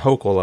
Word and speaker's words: Hochul: But I was seeing this stuff about the New York governor up there, Kathy Hochul, or Hochul, or however you pronounce it, Hochul, I believe is how it is.